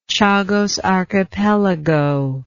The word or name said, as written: Chagos Archipelago